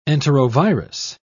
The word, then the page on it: enterovirus: British English